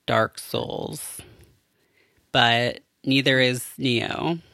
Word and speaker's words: Nioh: I've been playing Neo, and it's something. – Neo. Is that the game that I have seen people talking about? It's spelled N-I-O-H.